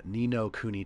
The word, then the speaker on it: Ni no Kuni: Ni No Kuni 2 Revenant Kingdom.